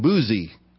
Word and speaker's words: Buzi: He's the son of, I love this, his dad's name, Buzi. That's how you pronounce it, sorry.